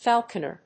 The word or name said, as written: falconer